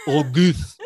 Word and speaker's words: Auguste: I'm like, Auguste. Like, it's in the back of your throat.